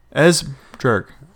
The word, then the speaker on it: Esbjerg: Esbjerg. So basically, okay. Silent B. Esbjerg.